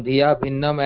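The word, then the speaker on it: dhiyābhinnam: But this term Dhiya Bhinnam can also break down as Dhiya Abhinnam. The A is silent there. So somebody can break this down, Dhiya Bhinnam, as one who sees, one who perceives Lord Shiva to be completely different.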